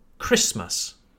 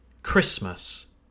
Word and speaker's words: Christmas: Do you hear how the T in Christmas is silent? That's right. We don't pronounce the T in Christmas. It's strange, I know. When we talk about Jesus, we do indeed say Jesus Christ. We say the T in Christ. But I'm afraid that in the word Christmas, we don't pronounce the T. Christmas.